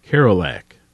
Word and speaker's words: Kerouac: Kerouac.